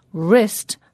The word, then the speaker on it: wrist: We spell this word wrist, W-R-I-S-T. But when we say it, we don't pronounce the first letter W, W. Instead, we start the sound from the second letter in the word, which is the letter R, pronounced R.